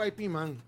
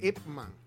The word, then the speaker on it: Ip Man: Sí, Ip Man, pero yo le digo IP Man. Es Ip Man, sí.